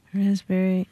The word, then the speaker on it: raspberry: K-N-E-E-L, Neil. – Raspberry, answer. – Silent right. letters. – Yes, silent letters. – Right. – Raspberry is the silent S. – P. – Well, P. Sorry. – Silent P, right?